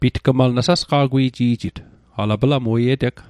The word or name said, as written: sasqa'gwiji'jit